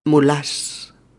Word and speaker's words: mulás: Mm, por favor, poned especial cuidado en pronunciarla como palabra aguda que es, acentuada en la última sílaba. Los mulás.